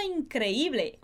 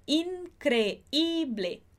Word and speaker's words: increíble: se esse i tiver acento, tá bom? Então, fica increíble. Increíble. Se eu não tivesse acento, essa palavra seria pronunciada de uma forma diferente. Ficaria increíble. Increíble. E não increíble. A diferença é increíble, increíble. Perfeito? Só para você entender. Essa palavra increíble, sem acento, ela não existe, tá? Só tô fazendo essa diferenciação para você entender como que esse acento faz diferença mesmo na hora de pronunciar a palavra, tá bom?